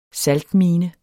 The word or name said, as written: saltmine